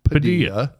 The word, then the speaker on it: Padilla: Or as it's properly pronounced, Padilla.